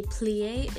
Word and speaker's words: plié: So at dance, well, in ballet, there is something called a plié. It's spelled P-L-I and an E and an accent over the E.